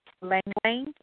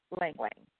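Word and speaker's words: ylang-ylang: Lang Lang. It's spelled Y L A N G, and that's okay. – You know, it's two. It's two, the same word twice, Lang Lang.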